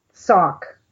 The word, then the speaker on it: Sauk: Sauk. – Sauk Indians. – S-A-U-K. – S-A-U-K.